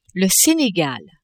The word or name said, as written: Senegal